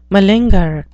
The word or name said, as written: malinger